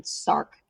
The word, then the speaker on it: SRC: I was going to say, they wound up calling this gene SARC, as in like sarcoma, but we just spell it SRC.